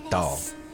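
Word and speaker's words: Tal: It's T-A-L, but I believe it's pronounced like a Dahl.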